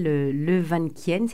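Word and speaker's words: Le Van Quyen: Vous avez évoqué le chercheur en neurosciences à l'INSERM, là, Michel Levanquien, c'est comme ça que vous le prononcez. – Ça, oui.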